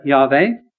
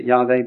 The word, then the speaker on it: Yahweh: The Lord, Yahweh, we'll talk about that word in a minute, YHWH. You can pronounce it Yahweh if you want. I pronounce it Yahweh because modern Hebrew would pronounce it that way.